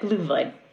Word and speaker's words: Glühwein: How do you actually pronounce it? – Exactly like you said, Glühwein. – Ah, grand. Glühwein.